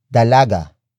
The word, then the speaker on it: dalaga: Then finally, we add the ng linker to connect this adjective, which ends in a vowel, to its noun, which is dalaga. Dalaga. Dalaga. Dalaga is spelled as D-A-L-A-G-A. It means young woman, the noun being described as the most beautiful.